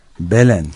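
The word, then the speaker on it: Belém: Belen şehrinde, Belem diye yazılıyor ama yanılmıyorsam Belen diye okunuyor.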